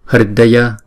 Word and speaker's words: hridaya: Hridaya, Hridaya. Chữ R có dấu nặng này chúng ta đọc là Ri.